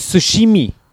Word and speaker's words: Tsushima: We get any Ghost of Omashimi? – Ghost of Tsushima. Wait, that's what I said. Tsushima? Ghost of Tsushima. It's the T is silent for some fucking reason.